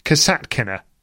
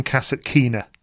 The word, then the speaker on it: Kasatkina: Yasmin says for people to learn how to pronounce Kasatkina. rather than Cassatt Keener.